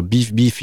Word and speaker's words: Bif: Beef, alors Beef, il faut, pour les auditeurs, ça se prononce comme ça, mais ça s'écrit B-I-F pour le premier Beef et B-I-2-F pour le deuxième.